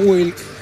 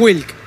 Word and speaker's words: Wilk: Es verdad, Alan Wilk. El Alan lo relaciona a un escritor. – Ni hablar, pero el apellido es WLK, así se escribe. – Ya quedamos en Wilk. – Se pronuncia Wilk, exactamente.